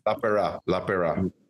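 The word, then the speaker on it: Lapeira: Paul Lapera? How do you say that? – Lapera.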